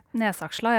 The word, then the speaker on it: Nesaksla: Roy hadde jo også uh, eh, sammenligninga av eh, den her nydelige restauranten på toppen av eh, byfjellet Nesaksla, eller hvordan skal vi uttale det her? Nesaksla, jeg tror på.